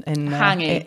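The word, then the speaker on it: hangi: And turned up and they'd made a hungy. Hopefully I've said that right. And I'm not very good at my pronunciation in hangy. – Hangy. Yeah. Okay, cool.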